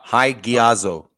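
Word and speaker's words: hagiazo (ἁγιάζω): It's 16 times, and it's translated from that word right there you see on screen, which is hagizio. Did I pronounce that properly? Hagizio? Hagiazo? – Yeah, hagiazo. Yeah.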